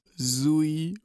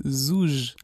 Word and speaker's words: Zouj: Weil ja, weil nobody around here pronounces it right. Just repeat it after me. – Nee, es ist Zouge, Zouge, Zouge.